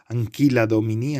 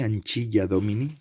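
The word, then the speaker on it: Ancilla Domini: traídos del libro Anquila Domini, Anchilla Domini, según como lo queréis decir vosotros, según la eh, pronunciación italiana o la pronunciación más eh, clásica, Anquila Domini se dice ahora, se pretende decir ahora en la, pronunciación clásica que me han enseñado a mí en el colegio y en la universidad. Mm, es, son textos sabrosos.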